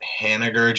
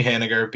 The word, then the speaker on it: Haniger: if I would say maybe Haniger, just because – is it Haniger or Haniger? – It's a hard G, I believe.